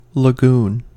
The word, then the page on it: lagoon: American English